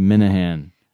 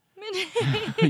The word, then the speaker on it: Minehan: Uh, Christine Lee Minehan. – No, Christy Lee Minahan. Minehan. you keep on saying it wrong.